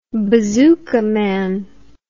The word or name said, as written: bazooka man